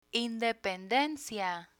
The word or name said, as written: independencia